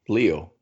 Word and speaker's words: Leo: And this is your co-host, Leo, which rhymes with video.